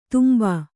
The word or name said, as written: ತುಂಬು